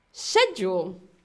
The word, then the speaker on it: schedule: So, we have schedule. Schedule. So, as you can see in the picture, we have someone's schedule. So, shed, shed, yule, shed, yule.